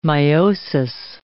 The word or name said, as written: miosis